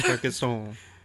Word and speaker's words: Carcassonne: Carcassonne. You don't pronounce the last couple letters. – Oh. – That's how French works.